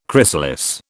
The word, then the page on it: chrysalis: British English